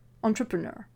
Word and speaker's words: entrepreneur: Par exemple, pour réussir à prononcer entrepreneur, entrepreneur, qui est difficile car c'est un mot transparent et que nous avons déjà une idée très précise sur la façon dont il devrait se prononcer, commencez par la fin. Ner, entrepreneur, entrepreneur.